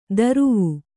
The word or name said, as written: ದರುವು